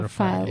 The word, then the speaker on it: Rafael: Rafael.